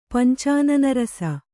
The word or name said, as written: ಪಂಚಾನನರಸ